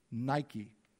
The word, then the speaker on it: Nike: The way we pronounce it in English is Nike. You ever see the brand Nike? That's what they name it for.